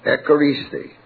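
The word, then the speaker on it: Echaristi (ἐχαρίσθη): Write it down in English letters. E. C-H or K, C-H would be better, E-C-H-A-R-I-S-T-H, long E, Echaristi.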